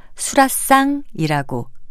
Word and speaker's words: 수라상: 수라상에서는 사이시옷은 쓰지 않고요. 그저 발음을 수라상이라고 된 소리로 합니다.